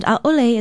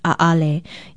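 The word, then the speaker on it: aole (ʻaʻole): Aloha, aole spelled okina e okina ole means no, and aole is our Hawaiian word of the day from Hawaii Public Radio. Aole is an interjection which also means not, never, and you may hear it pronounced aale.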